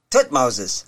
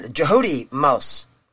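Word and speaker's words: Thutmose: And we got to come up with Thutmoses. And the actual pronunciation a lot of Egyptologists use is Jehudi, the first part of that, Jehudi Mos. And so that's a better pronunciation than Thutmos, is Jehudi Mos.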